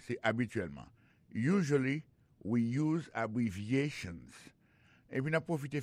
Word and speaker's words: abbreviations: En parlant de accent, accent tonique qui vient pour nous prononcer mot yo, n'a pas un mot abbreviations nan qui signifie abréviation. Abbreviations, accent tonique là, tombé sous troisième syllabe là.